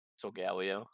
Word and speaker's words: Solgaleo: So- Solgaleo, Solgaleo, and Lunala, Lunala, Necrozma can confused with them.